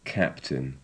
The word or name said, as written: captain